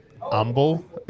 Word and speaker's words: Humble: It's pronounced Humble. – Humble. It's silent H.